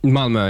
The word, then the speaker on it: Malmö: You get the reference if you listen to the previous episode are how do you say Malmö? – Malmö, yeah.